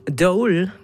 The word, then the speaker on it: Dool: Dole, wie man das Dole ausspricht, Dole, was ich mittlerweile mitbekommen habe,